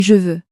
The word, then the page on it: je veux: french